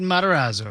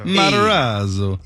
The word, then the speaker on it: Matarazzo: How do you say it? – Oh, I have no idea. Matarazzo? – Gatton? – I think you said it right. – Gatton. Gatton. Yeah. Matarazzo. – Gatton Matarazzo. – Matarazzo.